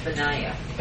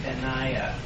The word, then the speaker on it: Benaiah: Benaiah. – Benaiah. Okay, Benaiah. You're doing the emphasis on the Nai.